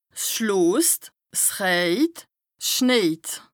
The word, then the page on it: 's schloost / 's rëjt / 's schnejt: Bas-Rhin; Strasbourg